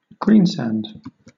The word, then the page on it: greensand: British English